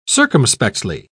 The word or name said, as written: circumspectly